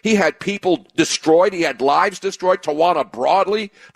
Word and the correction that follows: Brawley: the Tawana Broadley lie. Brawley, why can't I say her name?